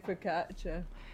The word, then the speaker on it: focaccia: Just listen to say focaccia.